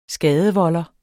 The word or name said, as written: skadevolder